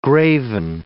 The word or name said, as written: graven